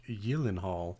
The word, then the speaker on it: Gyllenhaal: Is it Gyllenhaal? Is this how I've been pronouncing it? – Gyllenhaal, but it's like Gyllenhaal.